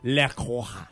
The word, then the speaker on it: La Croix: In France, it is said as La Croix.